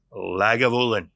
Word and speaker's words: Lagavulin: So, Lagavulin.